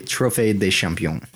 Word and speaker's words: Trophée des Champions: How do you pronounce that trophy? Because you took French. – The Trophée des Champions.